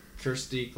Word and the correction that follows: Kiersey: So if you say her name wrong one more time with her name in front of you, I'm gonna kill myself. – Kirstie Clemons? – Kiersey. K-I-E-R-S-E-Y. – I'm looking at Kiersey Clemons.